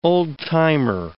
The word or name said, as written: old-timer